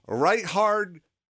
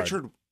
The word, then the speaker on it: Richard: Reinhard Wagner. Okay? – Reinhard. – Richard.